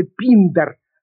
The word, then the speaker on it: Пиндар: Я могу сказать только, что античных поэтов и мыслителей, которых вы нам перечислили, правда, не совсем верно вы их перечислили, потому что Пиндар, именно так надо произносить имя этого греческого поэта,